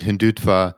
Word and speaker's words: Hindutva: You referred to this, and I'll get the pronunciation right, Hindutva philosophy,